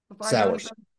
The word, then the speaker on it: Sauers: Sauers.